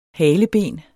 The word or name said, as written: haleben